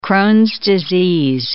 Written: Crohn's disease

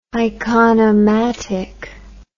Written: iconomatic

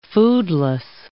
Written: foodless

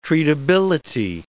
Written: treatability

